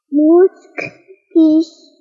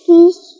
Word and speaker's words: Mutskish: ¿Cómo se dice niña en mije? – Mutskish. – ¿Mutskish? ¿Kish? ¿Kish? – Mutskish. – ¿Kish? Ah, Mutskish. Ah, niña pequeña. Ah, claro, Mutskish.